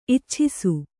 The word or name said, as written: ಇಚ್ಛಿಸು